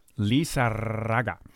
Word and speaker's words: Lizarraga: I hope I'm pronouncing that right. Lizarraga. – I would say it's probably about a 5% chance that you're pronouncing it right. – Lizarraga. I think I got that right, including the trill door. – That's about right, yeah.